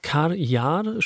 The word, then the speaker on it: Kar Yar: Ihr neues Album heißt Kar Yar, spricht man es aus, habe ich gerade gelernt.